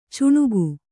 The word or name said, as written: ಚುಣುಗು